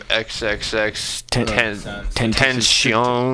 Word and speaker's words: XXXTentacion: We got fucking, I never know how to say his fucking name, XXX Tentacion.